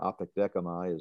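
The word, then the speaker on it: Apodekamai (ἀπεκδέχομαι): Apodekamai is the way that word is pronounced.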